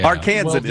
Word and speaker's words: Arkansan: Arkansan is the main term today.